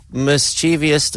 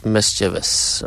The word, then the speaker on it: mischievous: And finally, mischievous does not exist. It's mischievous. All right.